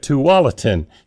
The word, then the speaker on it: Tualatin: He's currently a pastor at Tualatin. See, I said that right, didn't I, Ken? – Yes, you did.